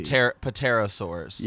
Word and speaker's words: pterosaurs: Try yeah uh, there's a P in front of that that, that word there. A dinosaur. You're not supposed to pronounce the P. – pterosaurs.